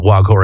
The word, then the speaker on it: Waghor: That's spelled W-A-G-H-O-R, the Wag Ho Aquarium.